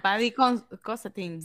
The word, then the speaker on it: Paddy Considine: Paddy Considine, sí.